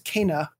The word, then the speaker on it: Kena: And it's also one of those things, now that you bring it up, there was a Game Informer cover story for Kena, which actually is pronounced Kena, according to them.